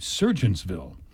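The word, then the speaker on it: Sergeantsville: It's not Surgeonsville. It's Surgeonsville. It's spelled S-E-R-G. – Right. – Surgeonsville is the correct pronunciation.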